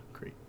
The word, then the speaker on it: Crete: C-R-E-T-E. – Yeah, Crete.